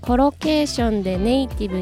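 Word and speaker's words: collocation: コロケーションコロケーションローの発音は L ですちなみにコロケーションよく一緒に使われる単語の組み合わせを意味する単語です。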